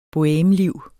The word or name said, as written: bohemeliv